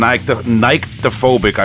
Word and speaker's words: Nyctophobic: Listen, right now I'm trying to talk about War Criminal Views, the classic album by German grindcore band Nyctophobic, I believe it's pronounced. It's NYCTOphobic. Nyctophobic is how I've always pronounced it.